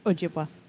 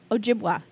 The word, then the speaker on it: Ojibwa: The Ojibwe... – Okay. – ...were, I believe, they... – Ojibwa. – Ojibwa. Thank you.